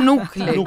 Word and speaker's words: knuckle: Com'è che è scritto knuckle? Knuckle? – Nucle. – Nucle. – C'è la bruttissima K muta, quindi non si legge.